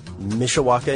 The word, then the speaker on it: Mishawaka: So Pat writes in from Mishawaka, Indiana.